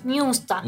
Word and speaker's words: Münster: Eh, a ver, uh, vos que estudias alemán me vas a ayudar. La Universidad de Münster, ¿se dice? – Münster. – Münster. – Ah, Münster.